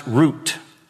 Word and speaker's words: Ruth: Now, in Hebrew, her name is pronounced without the TH sound. It's pronounced root, almost like the root of a tree. That's how her name is pronounced, root.